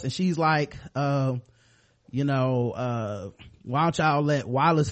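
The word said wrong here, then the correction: Wylis: fight, or Willis is they pronounced it, and Willis is Hodor.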